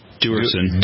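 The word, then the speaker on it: Duerson: Dwersen.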